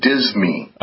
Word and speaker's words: disme: Spell that, because it sounds like you're saying Disney like Disney movies. – No, D-I-S-M-E, dizmy.